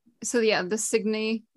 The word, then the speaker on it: Signe: And I never knew how to pronounce Signy because it's Signe. So I always thought it was Signe. But Signy sounds cuter.